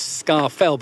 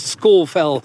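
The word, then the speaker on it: Scafell: Many people come up here and they call this Scar Fell, but of course it's Score Fell.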